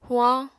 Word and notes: huañ: apatani

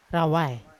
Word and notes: rawai: Yawa

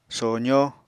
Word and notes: sozó: Apatani

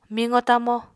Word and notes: miqo-tamo: Apatani

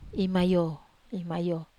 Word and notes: mayo: Wamesa

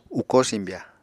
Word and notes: ukó simbya: Apatani

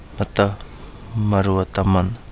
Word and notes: Mada Malua Damana: Kapingamarangi

